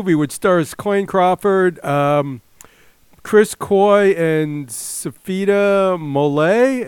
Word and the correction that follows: Mwafi: if I'm saying her name right? Mofi? – Safita Mwafi. – Oh, okay. – Mwafi. – Yeah.